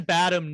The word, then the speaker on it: Badham: I think it's distant enough that it's like, Oh, by the way, I pronounce his name Bad Ham. – Oh, but I see Badham, he's English, and you're probably right. – I think you're right. I think it's Bad Ham.